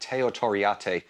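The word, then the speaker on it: Teo Torriatte: I think he did Teo Toriati, which was, excuse me, am I pronouncing that right? Teo Toriate.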